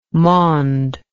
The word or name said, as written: maund